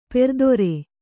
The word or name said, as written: ಪರಿದರ